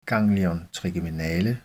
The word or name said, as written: ggl. trigeminale